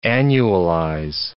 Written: annualize

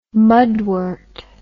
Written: mudwort

